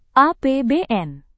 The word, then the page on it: APBN: indonesian